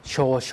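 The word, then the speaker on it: โช โ ช (少々): องรอนะครับกรณีทำงานรับโทรศัพท์เนี่ยก็คือลูกค้ารอสายนะครับก็ถือว่าเป็นการรอเหมือนกันนะครับก็ให้ใช้สำนวนนี้นะครับสำนวนบอกว่าโชโชมาจิอิตาดาเคมัสเดโชกะโชโชมาจิอิตาดาเคมัสเดโชกะเจอคำว่าโชโชอีกแล้วนะครับโชเสียงยาวแล้วก็โชอีกตัวนึงก็เสียงยาวนะครับออกเสียงรวมกันเป็น 1 คำ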